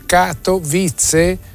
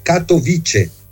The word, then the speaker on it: Katowice: Ora, la pronuncia esatta è Katowice. – A Katowice? – Sì, perché non è una lingua slava come le altre, quindi non ha l'accento sulla terzultima, quindi le parole non sono parossitone, tanto meno sdrucciole, ma sono piane, quindi cade sulla penultima, quindi Katowi, però la C non è la C che diventa Z delle altre lingue slave, è con la C, Katowice.